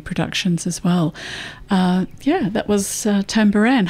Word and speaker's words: Tabaran: It's spelled T-A-B-A-R-A-N, but pronounced Tambaran. It means spirit house, and that's George Telek, T-E-L-E-K, a Papuan musician, there with Not Drowning Waving, David Bridey and Not Drowning Waving, from back in the 90s, actually.